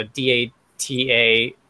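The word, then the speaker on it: Datasette: Spelled like cassette, so Dataset.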